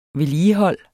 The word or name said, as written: vedligehold